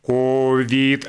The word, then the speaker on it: ковид: Он отметил, что есть люди, которые ставят ударение на первый слог «ковид», а скорее всего это те, кто много слушает или смотрит англоязычные СМИ. И господин Осадчий обратил внимание на то, что «ковид» с ударением на второй слог «ковид» произносят люди с образованием и без, молодые и старые. И это свидетельствует о том, что такая норма и сохранится в ближайшее время.